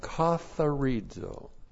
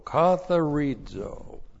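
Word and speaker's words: katharizo (καθαρίζω): All right, the verb katharizo. Looks like that in the Greek, K-A-T-H-A-R-I-Z, long O, katharizo.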